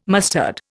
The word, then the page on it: mustard: Indian